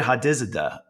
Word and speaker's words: Hadizadeh: Hadizada, right? – Very good.